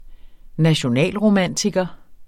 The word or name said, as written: nationalromantiker